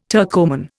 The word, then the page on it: Turkoman: Indian